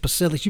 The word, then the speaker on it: Pasillas: If you're an American, and or if you're a real American, you pronounce it Pasillas. You pronounce every one of those fucking letters the way they're supposed to be pronounced.